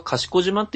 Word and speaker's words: 賢島: その、近鉄四日市で、賢島行きの、電車と、その賢島という漢字を見て、ああ、これは賢島って読むんだな、っていうのを知ってるぐらいなので。